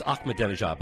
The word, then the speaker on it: Ahmadinejad: You are the only one who could pronounce Ahmadinejad before anybody else could. – It's Ahmadinejad, but thank you.